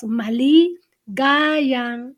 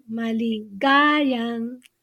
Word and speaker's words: Maligayang: And also, like, this Maligaya has four syllables. The stress is in the second to the last. That's why the stress in Maliga. Yeah. – Oh. Okay. – You pause. Maligayang. Maligayang Pasko.